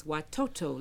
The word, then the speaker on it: watoto: Swahili word for children is watoto.